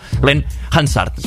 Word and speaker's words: Glenn Hansard: I això era el senyor Glenn Hansgar, perdoneu, Glenn Hansard, costa molt pronunciar el seu cognom.